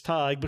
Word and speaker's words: Tig: I always pronounce it as Tig because it's short for Tiger.